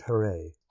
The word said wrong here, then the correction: Jerez: Jerez. – Thank you. Jerez.